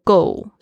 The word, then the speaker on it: goal: 那这边我就稍微介绍一下今天这个句子，My goal，大家特别注意一下，这个 goal 是我听到最多人就是发音会错误的地方，这个 o a o 的声音。然后加上 l，它是一个翘舌音，它的舌尖呢必须要顶在你的这个上排牙齿的这个后面的这个小肉这边，好像舌头抱住一颗乒乓球的感觉，去发出呃的声音。